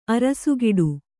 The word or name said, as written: ಅರಸುಗೊಡು